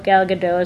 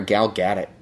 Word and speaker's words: Gal Gadot: How you say her name? – Yeah. – Oh my God, I've been calling her Gal Gadot.